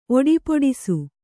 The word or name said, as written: ಒಡಿಪೊಡಿಸು